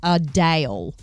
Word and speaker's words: Adele: She actually said that she prefers the correct pronunciation, but she's okay that everyone calls her Adele. Her name is pronounced Adele.